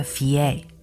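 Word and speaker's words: fé: 羊的冰岛语发音是 “fie”，也是 “money” 钱的意思。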